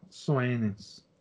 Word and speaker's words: Suenens: Havendo se pronunciado em aula, também por bocas cardinalícias, Ledger e Suenens, ah, Soenens.